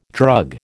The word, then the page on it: drug: American English